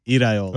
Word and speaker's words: Iraola: It rhymes with Areola? – Iraola.